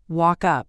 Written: walk up

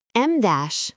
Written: em dash

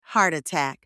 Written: heart attack

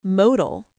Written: modal

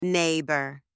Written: neighbour

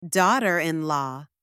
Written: daughter-in-law

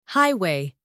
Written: highway

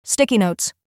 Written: sticky notes

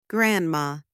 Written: grandma

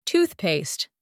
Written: toothpaste

